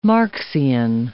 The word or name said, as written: Marxian